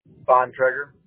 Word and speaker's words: Bontrager: Bontrager. – Okay. How do you spell that? B-O-N-T? – B-O-N-T-R-A-G-E-R. – A-G-E-R? – Yeah.